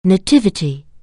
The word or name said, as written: nativity